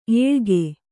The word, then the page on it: ಎಳಗ: eḷaga